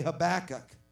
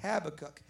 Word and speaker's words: Habakkuk: Everyone say Habakkuk. All right? Now, I do know that it's permissible to say Habakkuk, and many people do that. I'm not saying I'm going to be pronouncing words as close to the Hebrew as I can.